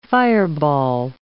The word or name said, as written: fireball